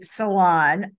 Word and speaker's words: salon: And that's basically salon. I have Jane Velez Mitchell, who has Unchained TV, said, pronounce it the French way.